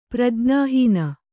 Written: ಪ್ರಜ್ಞಾಹೀನ

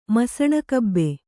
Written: ಮಸಣಕಬ್ಬೆ

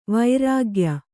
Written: ವರ್ಗೀಯ

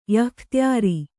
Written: ಯಃಖ್ತ್ಯಾರಿ

